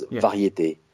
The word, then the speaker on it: Variété: If you pronounce it in French, it was Variété.